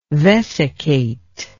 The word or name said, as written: vesicate